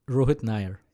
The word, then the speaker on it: Rohit Nair: We are live with Rohit, and I know you just told me, and I know I'm going to mess it up. Nair. – There you go. – Are you sure? Absolutely. You say your last name. – Rohit Nair.